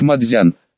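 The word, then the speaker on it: «мадзян» (麻雀): Слово «маджонг» читается в японском как «мадзян», но чтение «дзяну» очень нестандартное.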